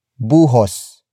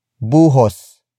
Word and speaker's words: buhos: Buhusan is spelled as B-U-H-U-S-A-N. It means to pour or douse onto something. Buhusan comes from the root word buhos. Buhos. Buhos, which is spelled as B-U-H-O-S.